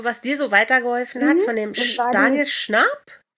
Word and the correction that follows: Schnarch: Schnarch? Also den schreibt man Schnarch, wie Schnarch.